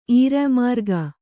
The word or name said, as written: ಈರ್ಯಾಮಾರ್ಗ